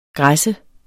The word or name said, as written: græs